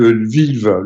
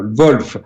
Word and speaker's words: Lviv: à côté de Lviv, Wolf, de la frontière, Wolf, c'est la prononciation russe, à la frontière polonaise, hébergeait un véritable camp d'entraînement de l'OTAN.